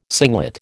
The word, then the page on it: singlet: American English